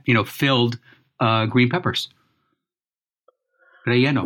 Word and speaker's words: relleno: Relleno. It's like a double L sort of thing. Relleno. In Spanish, and all you Spanish speakers, feel free to correct my pronunciation. Relleno.